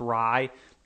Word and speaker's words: Rye: David Ray is the Packers' new assistant offensive line coach, or David Rye, perhaps, pardon me, I think it's pronounced Rye. Kind of looks like Ray when you just looking at it, but phonetically it's pronounced differently.